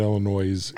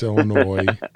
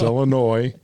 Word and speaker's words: Illinois: It's not Illinois. It's Illinois. – It literally ends with an S. – I know, but I'm actually from there. I know how it's pronounced. It's Illinois, Colorado State, and Michigan.